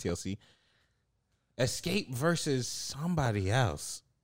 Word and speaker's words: Xscape: No, no, Escape is spelled X. – Phase up. – X S C A P E.